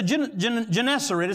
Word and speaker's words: Gennesaret: And then the Bible said when they finally did get to the other side of the sea, to Gennesaret, The Bible said to Gennesaret, is how you say it,